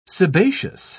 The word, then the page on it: sebaceous: British English